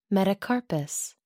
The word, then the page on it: metacarpus: American English